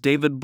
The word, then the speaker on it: David: My name is David Brown.